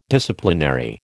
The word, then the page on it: Disciplinary: American English